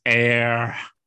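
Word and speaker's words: Éire: And he says, Well, looks like you got to go to Erie. And Seamus is like, ah, You keep saying it wrong. It's air.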